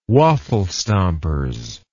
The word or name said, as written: waffle stompers